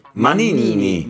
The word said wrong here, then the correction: Maninni: Maninni, ragazzi, Maninni.